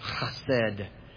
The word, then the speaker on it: chesed: This word pronounced in the Hebrew is chesed.